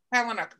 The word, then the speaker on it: Palahniuk: The book is brilliant too, by Chuck Palahniuk. Did I say his name? – Palahniuk, whatever.